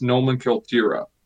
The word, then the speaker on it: nomenklatura: In Russian, it's pronounced nomenklatura.